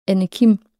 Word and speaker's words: Anne Kim: Der var der også en, der hed Anne, så vi var to andre i klassen, så det var også Anne Kim. Øh, når jeg skulle præsentere mit navn for engelsktalende, så forstår de, har de ikke ja, udtale, så det blev til Anakin yeah.